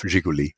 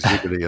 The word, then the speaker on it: Zhiguli: I'm glad you pronounced that. I would have got that completely wrong. ZH. Well, it became a car, of course. The beer was Zhiguli, and then the Zhiguli became the Lada.